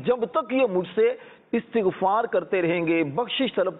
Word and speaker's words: استغفار: اصل تلفظ ہے استغفار,